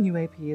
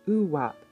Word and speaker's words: UAP: The term UAP is not commonly pronounced OOWAP, but it is commonly used by interested parties to sidestep the stigma surrounding UFOs, particularly the U.S.